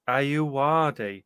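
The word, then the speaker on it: Ayoade: Ayuadi, and Matthew Holness.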